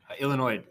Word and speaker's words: Illinois: Illinois, the S is silent at the end. Possessive.